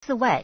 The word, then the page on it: 此外: cǐwài